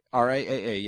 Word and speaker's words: RIAA: Now, on Torrent Freak, there was a somebody had found out that someone at the IRAA, or RIAA,